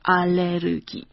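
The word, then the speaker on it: Allergie (알레르기): wie auch das Wort Allergie. Allerdings ausgesprochen, Allergie.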